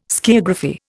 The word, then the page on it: skiagraphy: Indian